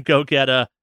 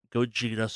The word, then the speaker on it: Gogeta: Janemba and Super Saiyan God, Super Saiyan Gogeta, Gogeta, sorry, I'll pronounce it right for a change, have been confirmed for Dragon Ball Fighter.